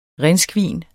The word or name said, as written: rhinskvin